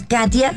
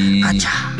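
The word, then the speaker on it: Katya: Sí, porque así se pronuncia, es Katia con Katy con Y, pero sí. Se pronuncia Katia.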